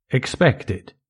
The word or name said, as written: expected